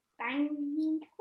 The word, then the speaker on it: Aubameyang: Aubameyang.